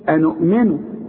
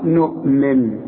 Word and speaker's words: نؤمن: في عندي ألف عليها الخمسة، وده اسمه حرف علة، يكتب ولا ينطق. أحسن تقول لي أنؤمن، تقول لي قالوا أنؤمن، لا دي الألف دي تبع إلى قالوا، قالوا نؤمن، خدت بالك؟